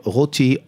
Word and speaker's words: Roty: Joseph Routy, R-O-T-Y.